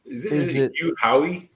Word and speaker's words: Hugh Howey: How do you say this guy's name? Is it Hugh Howey? – Hugh Howey, I think is how it's spelled.